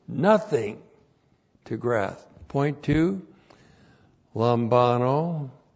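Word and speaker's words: lambano (λαμβάνω): L-A-M-B-A-N, long O, lambano.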